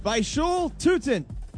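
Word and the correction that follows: Bhayshul Tuten: Basil Tootin, I believe, is the correct pronunciation.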